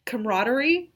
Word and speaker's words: camaraderie: Camaraderie? – Ship. – Okay, so no ship, just camaraderie.